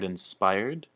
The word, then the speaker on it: inspired: So the word inspired, the emphasis is on the spy, inspired, which can be translated as 有灵感的.